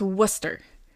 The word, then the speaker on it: Worcester: But it's pronounced, okay, it's W-O-R-C-E-S-T-E-R. It's pronounced Worcester.